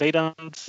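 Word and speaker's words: Weydandt: Genau, kleine, äh, kleine Feel-Good-Story hier von ähm, Hendrik Weidand, wenn er so ausgesprochen ich glaube schon, ja. Weidand, ja, der Weidand.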